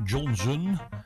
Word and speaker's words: Johnson: Michael Johnson, met een U, Johnson. Dat je niet denkt, hij spreekt het helemaal verkeerd uit. Nee, zo spreek je het echt uit.